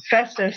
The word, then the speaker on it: Festus: Festus or something. – Festus, thank you.